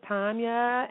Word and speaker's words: Tanya: Just making sure because my name is Tanya, and it's spelled T-A-N-Y-A, and most people like to pronounce it the American, you know, way, which is Tanya. But truth right. – be told, it's Russian, so it's a long A, or is that the short A?